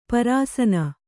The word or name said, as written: ಪರಸನೆ